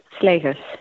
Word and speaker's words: Slegers: Uttalar jag ditt efternamn rätt? Ja, det var jättefint. Slegers, ja.